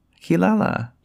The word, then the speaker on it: Kilala: So, Ki like Ki-E-Y and then Lala. So, Kilala. – Ki. Kilala. – Yes. – And Amer is how you say your first name. So Amer Kilala.